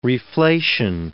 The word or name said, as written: reflation